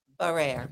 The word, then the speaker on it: Barrere: Barrere.